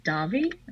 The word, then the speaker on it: Davi: Steve says, "Hi Gianni, did you ever meet actor and singer Robert Davi? Am I saying that right? – Oh my God, yeah.